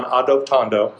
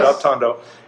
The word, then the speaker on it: Adoptando: But let's hone in on Adoptando. I think I said it right that time. – Yes. – Adoptando.